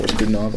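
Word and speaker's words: Godunov: Gudinov also a way to pronounce it.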